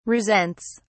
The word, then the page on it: resents: British English